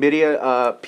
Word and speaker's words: birria: They had the fucking chicken, birria? Did I say it right? – Mm-hmm. – Okay, chicken birria, pizza.